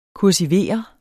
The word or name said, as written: kursivere